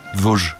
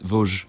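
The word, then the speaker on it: Vosges: Vosgos. Siempre hay ocho letras, se pronuncian tres. Sí. – Ah, o sea, sería... – Es Vosgos.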